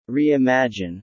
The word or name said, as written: reimagine